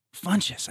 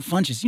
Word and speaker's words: Funches: I mean, aside from like Kevin Smith and our friend Ron, I want to say Fuchs, right? I want to say his name, right? – Funches? – Funches. I never say it right. Ron Funches.